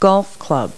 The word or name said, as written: golf club